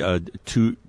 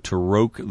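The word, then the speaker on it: Toruk: No, it's actually Taroque. – Taroque, is that how they say it? – Yeah. Correct pronunciation. Taroque, the first flight.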